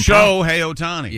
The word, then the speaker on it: Shohei Ohtani: Shohei Ohtani. – Yeah, there you go. – Yeah. I got an emphasis on the show. Syllable.